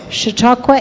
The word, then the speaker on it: Chautauqua: Chautauqua is a noun.